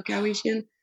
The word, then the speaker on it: Kaeowichien: No, so we have a son named Lachlan, and we almost named him Lando, so it would be Lando Kaeowichien. – Yes! – But that will help you remember how to say it, right? – Yeah, right.